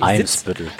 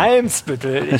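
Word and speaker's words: Eimsbüttel: Ich sitze und schaue Ali Mitgutsch wimmelbildmäßig auf den Weihnachtsmarkt von Hamburg Elmsbüttel. Hoffe ich zumindest, dass ich das jetzt richtig gesagt habe. – Elmsbüttel. – Genau. Elmsbüttel.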